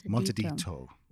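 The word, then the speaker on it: montadito: Um, but the last thing is just to bear in mind is that montaditos. hang on, am I saying this right? – Montedito. – Montedito. Montedito.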